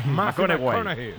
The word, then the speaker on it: McConaughey: Hay que ir corrigiendo la pronunciación. – McConaughey. McConaughey. Bueno, pues el Mateo se pone.